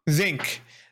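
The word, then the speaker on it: Xenk: I did enjoy when how do you say his name? Zanik? – Zink? – Zink. Zink. – Or when Zink. I'm pretty sure Zink, yeah. – No, you're right.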